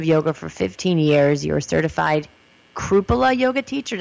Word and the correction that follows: Kripalu: Did I say that right? Kripala. – It's called Kripalu. – Kripalu. I did that wrong like seven times.